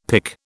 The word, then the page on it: pic: British English